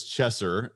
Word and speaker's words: Chesser: people would say hey cheeser right they they, they would mispronounce my name cheeser and so i was in a fraternity and the guys said hey you know, is Tyler Cheeser here? And of course, I'm like, hey, it's Chesser, guys, let's go.